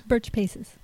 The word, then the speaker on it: Burch-Pesses: Burch-Pesses.